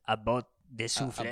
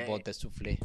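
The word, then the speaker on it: À bout de souffle: How do you say it in France? About de souffle. – About de souffle.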